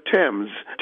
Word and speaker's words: Thames: We still pronounce the name of the river Thames, even though it is spelled with a TH. And the same is true of Thomas.